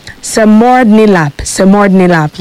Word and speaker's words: semordnilap: Samordnilap, yeah. It's spelled S-E-M-O-R-D-N-I-L-A-P.